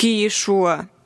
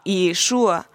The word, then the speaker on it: Иешуа: Он нарочно произносит это имя неправильно и называет его сокращением Иешу, что означает «да будут стерты имя его и память о нем». А Иешуа на иврите на самом деле означает «спасение».